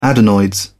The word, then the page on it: adenoids: British English